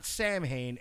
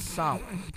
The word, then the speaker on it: Samhain: It's not Samhain, it's Samhain. Okay. It's not pronounced fucking Samhain, it's pronounced Samhain.